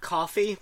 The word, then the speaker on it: Ko-fi: I guess this is pronounced coffee, but it's spelled K-O-F-I. I only think it's pronounced coffee because the tagline is that you can buy artists you like a cup of coffee.